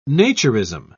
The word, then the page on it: naturism: British English